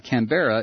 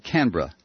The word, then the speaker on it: Canberra: By the way, Canberra is pronounced without the bear, so it's not Canberra, it's more like Canberra.